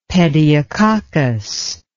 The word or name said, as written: pediococcus